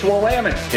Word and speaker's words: Willamette: It's Willamette. – Yeah. Willamette.